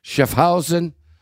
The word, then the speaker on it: Schaffhausen: And the World Men's Championships will get underway on Saturday in Schaffhausen. Did I say that right? – Sure. Schaffhausen, I think. – Yeah, Schaffhausen. – Schaffhausen. But you have to get the accent on the right syllable. Schaffhausen, Switzerland.